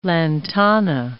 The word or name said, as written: lantana